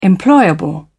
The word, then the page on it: employable: British English